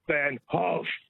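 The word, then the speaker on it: Van Gogh: Now, this is controversial the way you pronounce this, but it's really pronounced Van Hoff. – Van Hoff, like that. – Not Goff or Go. Somebody corrected me on it once. I thought, gee, I better check this out. – Vincent Van Hoff. – Hoff is the way they pronounce it in the Netherlands, yes.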